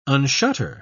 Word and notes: unshutter: British English

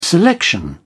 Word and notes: selection: British English

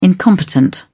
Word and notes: incompetent: British English

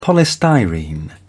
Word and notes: polystyrene: British English

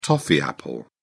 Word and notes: toffee apple: British English

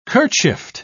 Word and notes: kerchiefed: British English

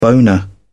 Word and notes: boner: British English

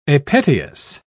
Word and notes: apetalous: British English

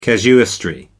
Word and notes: casuistry: American English

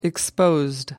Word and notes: exposed: American English

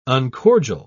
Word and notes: uncordial: British English

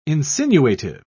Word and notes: insinuative: British English

